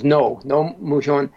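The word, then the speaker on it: Roh Moo-hyun: So president, it's always spelled R-O-H, Roe, but his name was Nomu Hyun.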